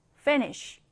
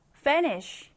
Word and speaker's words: finish: 자, 이 단어의 이 sh 발음은 sh. sh. 이렇게 발음됩니다. 그래서 finish. finish. 이렇게 발음을 해주면 돼요. 자, 우리 먼저 스펠링 확인하면서 정확하게 한번 따라 써보겠습니다. finish. F-I-N-I-S-H. 자, 강세는 어디에 들어갈까요? Finish. 맨 앞에 넣어주시면 됩니다.